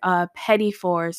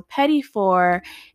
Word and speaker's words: petit four: But it's a pastry word, pétifort. So pétifort is spelled P-E-T-I-T-F-O-U-R. And it's something that's used across the culinary world, but definitely in pastry.